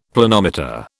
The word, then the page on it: Planometer: British English